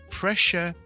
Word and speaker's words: pressure: 一个开心 pleasure P-L-E-A-S-U-R-E 听住 pressure and pleasure，咁大家听到个发音咧，其实个分别都唔细嘅，有两个地方分别啦。第一个系 pressure P-R-E，当然系 pre-re-re 卷嚟 re 爱音 pressure pressure pressure，第二个系 pleasure，一个系 l 音 ple ple ple ple ple，同埋。